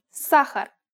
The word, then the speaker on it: сахар: It's pronounced сахар and means sugar.